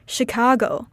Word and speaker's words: Chicago: The state of Chicago in the United States is also pronounced like Chicago with a sh sound rather than a ch sound.